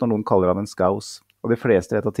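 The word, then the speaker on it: Scouse: Lapskaus på liverpoolsk heter skaus, uttales skaus I en stavelse.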